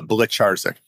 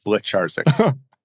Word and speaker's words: Blecharczyk: Uh, Blecharczyk. Blecharczyk.